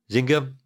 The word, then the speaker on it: Zynga: Take-Two acquired, was it Zynga? Is that how you pronounce it? – Zynga.